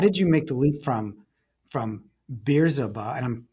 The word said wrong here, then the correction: Bizerba: So the exact pronunciation is Bizerba, Bizerba.